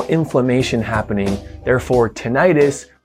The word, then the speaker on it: tinnitus: The correct way to pronounce the word is tinnitus. The other way to pronounce the ringing in your ears is tinnitus. That uses the section itis. That typically refers to inflammation like laryngitis, inflammation of the throat.